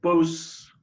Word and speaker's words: Bausse: Well, it should be Bausse normally. – Bausse. – More the French pronunciation.